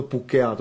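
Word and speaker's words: puhkeaa: Píše se to puh-ke-a-a. Ale vyslovuje se to pukea, to jste taky mohli slyšet, bylo to krásně slyšet.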